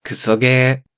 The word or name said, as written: Kusoge